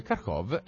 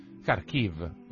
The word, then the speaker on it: Kharkiv: Perché eh, Kharkov è la pronuncia russa di una città che in questi giorni stiamo sentendo molto molto spesso nelle cronache. Kharkiv, è ehm, una città ucraina eh,